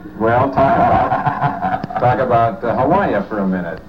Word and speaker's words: Hawaii: When I say Hawaii, I think, should it be Hawaii? How do you say it over there? – You say Hawaii.